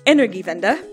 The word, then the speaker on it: Energiewende: What was that German word that you pronounced so poorly? Well, that word is, I'm going to try it again, Energiewende, and we'll get there, but it essentially means energy transition.